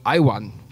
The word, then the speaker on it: IAWN: IAWN, that's called IWAN, we decided to pronounce it.